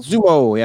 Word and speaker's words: Xumo: Next one up is, how do you pronounce it? Zumo? X-U-M-O? Zumo? – Zumo, yeah.